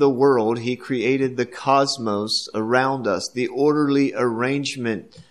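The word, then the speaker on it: cosmos (κόσμος): The word is Cosmos, K-O-S-M-O-S, another fairly familiar word with us because we have an English word that is very much the same, and that is C-O-S-M-O-S, cosmos, pronounced the same way.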